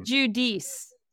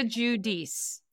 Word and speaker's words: Giudice: The Italian pronunciation is Giudice. – Giudice. – Giudice, sure. Oh, so that counts. Okay. You can be authentic if you'd like. Versus Brooklyn Italian pronunciation.